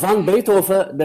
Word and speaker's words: van Beethoven: Ama van Beethoven demek de bana ters. Yıllarca van Beethoven dedikten sonra şimdi aslında ama Flamanca okunuşu öyle. Van Beethoven.